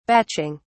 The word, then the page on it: batching: British English